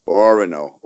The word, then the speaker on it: Orono: Orono.